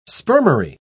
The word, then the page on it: spermary: British English